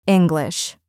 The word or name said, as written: English